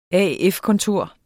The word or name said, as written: AF-kontor